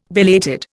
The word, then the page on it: belated: Indian